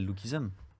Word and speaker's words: Lookism: Lookism, comment tu prononces mal I'm American. non, c'est Lookism.